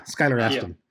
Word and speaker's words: Skylar Astin: Astin, am I saying it right? – Yeah, Skylar Astin.